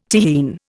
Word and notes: Tyrrhene: Indian

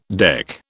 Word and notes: deck: British English

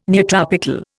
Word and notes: neotropical: Indian

